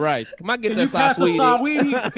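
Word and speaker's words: Saweetie: It's almost like saying sweetie, but there is a little A sound before. Saaaweetie. – Saaaweetie. – You actually pronounce the A. Saaaweetie.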